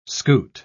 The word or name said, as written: scoot